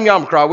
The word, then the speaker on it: Yamacraw: In downtown, how you say it again? – Yamacraw. – All right. That's strange. So that means tomorrow night, Monday night, in downtown Yamacraw, we're going to have 300 people at church.